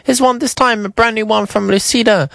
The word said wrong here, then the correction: Lucinda Wilson: Lucida Wilson. Lucinda Wilson, I think that's how you say her name.